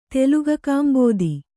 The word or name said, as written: ತೆಲುಗಕಾಂಬೋದಿ